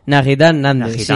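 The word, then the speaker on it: Nahitan Nández: Naitán Nández. Naitán, perdón. – Eh, bueno, creo que se pronuncia así, por lo menos cuando yo veía partidos de él, lo pronunciaban así.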